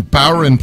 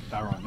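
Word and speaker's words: bodhrán: And Bowron player, which is spelled Bod Haron, but it's uh, Bowron player, I believe. Yeah. Right? Isn't that how you say it? Bowron.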